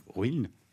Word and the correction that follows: Rijn: Rein. – Je ne sais pas le prononcer. – Rein. Ça s'écrit R-I-J-N. Et pareil, on me l'a mis. Hein. Prononcer Rein. Pour se faire chic.